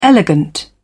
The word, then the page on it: elegant: British English